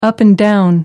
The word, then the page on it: up and down: American English